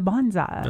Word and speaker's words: bonsai: Yeah, see, if your plants suffer, just turn them into bonsais. – That's right. – Bonsai. – Bonsai. – Neither syllable is accented.